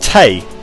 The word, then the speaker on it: Tey: It's pronounced Tay. It is Tay, as in T-E-Y.